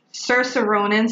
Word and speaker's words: Saoirse Ronan: Saoirse Ronan. Sorry if I said that name wrong. – No, you said it right.